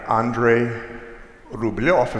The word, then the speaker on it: Andrei Rublev: This is the icon by a guy named Andrei Rublev, is how you say it in Russian.